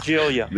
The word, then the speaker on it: Giglia: Giglia.